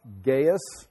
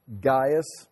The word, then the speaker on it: Gaius: but it's pronounced Gaius, Gaius, or Gaius. Like, the instead, it'd be like G-E-Y-E-S, Gaius, or just Gaius, or Gaius. And so, it seems to be acceptable in many different ways.